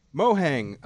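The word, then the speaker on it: Mojang: Is it Mohang? Is that the company? – Yeah, that's how I pronounce it. – That's how it looks to me. Mohang, the company that made Minecraft, or Notch's company, I guess.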